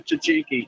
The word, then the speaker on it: tzatziki: I asked them how you pronounce it, and they all pronounced it correctly, tzatziki.